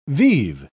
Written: vive

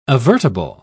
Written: avertable

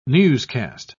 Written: newscast

